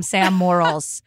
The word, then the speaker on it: Morril: I'm just going to call him Sam Morrills.